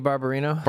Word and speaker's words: Barbarino: Did I pronounce that correctly? Is it Barbarino? – Vinny Barbarino. – Barbarino.